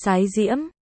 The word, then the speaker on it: Thái Diễm: Thái Văn Cơ, Thái Diễm, cũng đọc là Sái Diễm, tự Chiêu Cơ, nhưng sau trùng húy với Tư Mã Chiêu nên người đời sau đổi thành Văn Cơ.